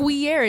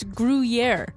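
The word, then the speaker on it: Gruyère: It's not Gruyere, it's Gruyere.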